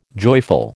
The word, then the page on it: joyful: American English